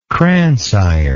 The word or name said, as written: crance iron